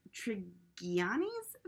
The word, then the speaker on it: Trigiani: But it's really billed as Adriana Trigiani's Very Valentine. – Trigiani? I think it's a soft G.